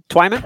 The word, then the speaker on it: Twyman: Twyman? – Twyman, yeah.